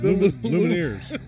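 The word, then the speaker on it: Lumineers: It's the Luminaries. How do you say it? – Luminaires. – Luminaires. Luminaires. Sorry.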